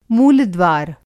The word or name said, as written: मूलद्वार